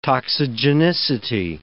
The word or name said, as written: toxigenicity